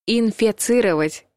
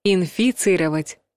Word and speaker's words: инфицировать: Инфицировать — неправильный вариант. Инфицировать — правильный вариант.